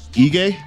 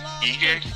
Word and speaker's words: Ige: Well, how would they say this dude's last name? – Ige. – Ige. – Yeah. Ige. Yeah, that's how they were pronouncing it.